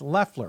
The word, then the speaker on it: Loeffler: It's Leffler. Imagine the O's not there.